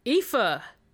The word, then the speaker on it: Aoife: Her name will be Aoife. – Oh. – That's okay. – That's just a... – Yeah, it's pretty nice. A nice Irish name. – Standard Irish name, yeah. – Which is spelled A-O-I-F-E.